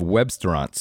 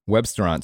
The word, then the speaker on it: Webstaurant: On a snowy January morning in Lancaster, Pennsylvania, a flat-screen monitor in a former Mennonite elementary school displays the vital signs of Webstaurant Store, spelled like restaurant but with web at the beginning.